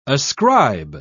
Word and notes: ascribe: British English

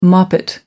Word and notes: moppet: American English

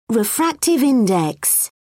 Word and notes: refractive index: British English

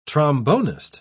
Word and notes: trombonist: British English

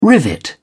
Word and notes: rivet: British English

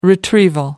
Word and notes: retrieval: American English

